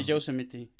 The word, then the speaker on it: Yosemite: ¿Cómo se pronuncia? – Eh, el Parque Nacional este. No, idea, pregúntale yo, Yosemite. Yo creo que es algo como Yosemite. – sí, Yosemite. Acaba en...